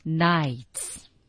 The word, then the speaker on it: knights: 第四句说呀 ,many brave knights. Knights 是什么？Knights 就是我们说古代的骑士。 – 注意 K 字不发音。